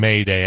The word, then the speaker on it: Mayday: So you're not saying Mayday, M-A-Y-D-A-Y. You're saying M-A-I-D-E-R. You just don't know it. – Right. – Right. Yeah. The pronunciation is identical.